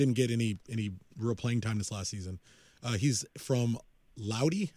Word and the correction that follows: Lodi: I think it's pronounced, California, from Central Cal. – It's Lodi. – How do you pronounce it? – Lodi. – Lodi?